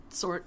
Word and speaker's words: Tzort: The Great Pyramid of Tzort. – I think the T is silent. Just did Tzort. – Sort.